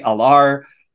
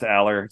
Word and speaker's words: Allar: Drew, praise be, Alar Allar. It's actually pronounced Allar, so I'm just kind of having fun with that.